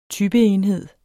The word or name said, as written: typeenhed